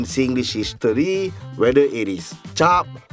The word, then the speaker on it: cap: The thing is, the word chop is a variation from the Malay word chap, pronounced chap, okay? But we spell C-A-P, which is to stamp.